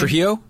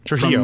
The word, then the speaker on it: Trujillo: Trujillo. Yep. – Trujillo.